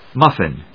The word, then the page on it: muffin: muf・fin; mˈʌfɪn